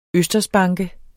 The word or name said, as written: østersbanke